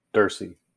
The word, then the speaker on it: Derse: And how do you pronounce your last name again? – Derse. – Okay. I couldn't remember if it was Derse or Derse. – Stupid French pronunciations.